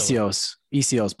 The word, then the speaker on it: Essihos: Properly. Essihos. But you did a great job. We just Essihos. Don't pronounce the H as much.